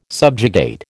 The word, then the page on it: subjugate: American English